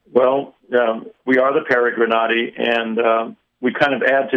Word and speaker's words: Peregrinati: I'm going to try to pronounce it Peregrinati. I may or may not have gotten it correctly, but we're grateful to have them. And they're going to tell us about the work they do. They're musicians, amongst other things. How are you all today? – Oh, we're great. You did great on the pronunciation.